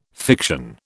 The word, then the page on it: fiction: British English